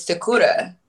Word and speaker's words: Sakura: and I used to pronounce it Sakura, which it's Sakura. – And Sakura. Sakura. – Yeah. Yeah, okay. And you roll the, like, you are, so Sakura. – Sakudra. – Yeah, you'll get it.